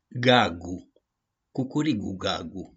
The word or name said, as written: GAGU-CUCURIGU-GAGU